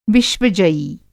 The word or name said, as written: विश्वजयी